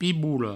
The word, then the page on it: pibola: Provençal; piboulo